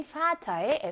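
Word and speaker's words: Vater: 아빠라는 의미를 지녔던 단어인 Vater의 F는 F 발음을 내게끔 되어 있습니다.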